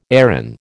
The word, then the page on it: Erin: American English